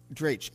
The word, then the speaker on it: Drache: Is it Drache? – That's right. Drache. Okay. – Drache.